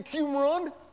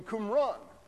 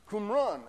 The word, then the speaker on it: Qumran: A lot of people come up to me and say, "Oh, Professor Eisman, do you mean Qumran?" No, we mean Qumran. Qumran, and people have to practice saying it.